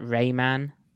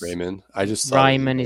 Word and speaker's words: Raimann: So we should probably touch on the unfortunate Bernhard Raimann, but apparently Americans can only pronounce that Rayman because Raimann is way too difficult, even though he's actually Austrian.